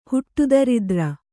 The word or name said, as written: ಹುಟ್ಟುದರಿದ್ರ